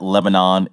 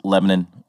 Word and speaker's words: Lebanon: Yeah, you know, it's not Lebanon, it's Lebanon.